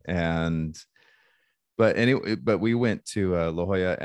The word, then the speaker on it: La Jolla: I say that wrong, don't I? Is it La Jolla? – La Jolla. – Yeah. La Jolla.